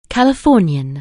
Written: Californian

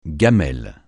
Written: gamelle

